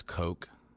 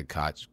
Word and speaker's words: Koch: Maybe like one of those cool like Heckler, and I think it's Coke. Although people always say Koch. They say Koch, but I think it's pronounced. – Is it Coke.